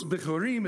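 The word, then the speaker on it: Bikkurim: So now, after first fruits, Bichurim is how they say it, and ye shall count unto you from the morrow after the Sabbath, from the day that you brought, don't worry about this.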